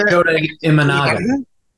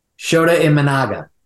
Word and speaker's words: Shota Imanaga: Shota, Shota Imanaga. – Shota Imanaga.